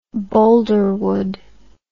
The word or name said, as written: Boldrewood